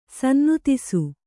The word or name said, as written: ಸನ್ನುತಿಸು